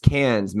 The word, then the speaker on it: Cairns: You're not letting me explain that in Australia, they don't pronounce their R's. And if you do, you sound like a hillbilly. And so when I was like, we're going to the Great Barrier Reef up in Cairns, all the Australians looked at me. They're like, it's Cairns, mate. you say Cairns, but yes, it's spelled C-A-I-R-N-S.